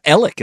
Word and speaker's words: Alex: Or Alec, as they say it down in the low country. – Yeah. Okay. His name is spelled Alex. – Just like we know Alex to be. – Yeah. Murdaugh. Okay. That's how you spell his name. – Yes. – But yet they call him Alec Murdaugh.